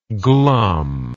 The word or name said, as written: glumness